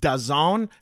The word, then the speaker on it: DAZN: And then when it said, when I looked it up, it said that it's pronounced Da Zone.